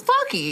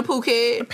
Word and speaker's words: Phuket: It is Phuket. I thought it was fucky.